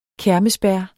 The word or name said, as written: kermesbær